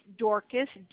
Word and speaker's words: Dorcas: Again, it's Dorcas, D-O-R-C-A-S. And the last name is spelled S-P-E-A-R-S.